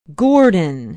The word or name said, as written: gordon